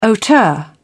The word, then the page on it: hauteur: British English